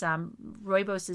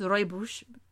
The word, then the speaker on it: rooibos: It's rooibos is Afrikaans for red bush. It sounds sort of Dutch, right? So it's properly pronounced rooibos. Rooibos.